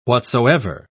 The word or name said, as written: whatsoever